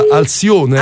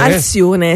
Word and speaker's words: Alcione: È in una versione di un'artista brasiliana che si chiama, come la pronuncia? Alcione. – Alcione, sì.